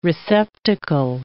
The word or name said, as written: receptacle